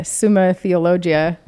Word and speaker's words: Summa Theologia: When I went to the Summa Theologia, how do you say that? – That's good. Is that right? – Yeah.